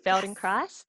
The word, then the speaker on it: Feldenkrais: Yes, that you said it correctly, Feldenkrais.